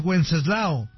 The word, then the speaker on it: Wenceslao: ¿Cómo se escribe? W-E-N-C de casa E-S-L-A-O. Lao. San Wenceslao.